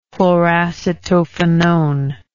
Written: chloracetophenone

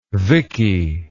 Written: Vicky

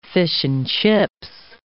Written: fish-and-chips

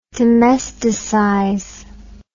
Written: domesticize

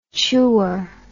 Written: chewer

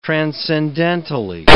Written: transcendentally

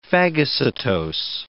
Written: phagocytose

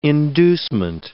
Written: inducement

